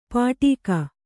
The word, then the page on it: ಪಾಟೀಕ: pāṭīka